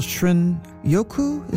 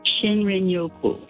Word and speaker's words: Shinrin-yoku: I think it's called shrin yoku. Is that how you pronounce it? The Japanese term for wood air bathing. – Shinrin-yoku.